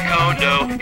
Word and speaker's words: Kondo: Like how to say Kondo, it's not pronounced Kendo.